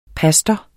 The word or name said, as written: pastor